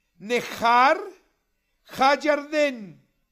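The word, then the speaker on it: Nehar HaYarden (נהר הירדן): presentamos a ustedes imágenes que corresponden al río Jordán, que en hebreo se pronuncia Nehar HaYarden.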